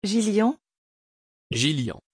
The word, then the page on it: Gillian: French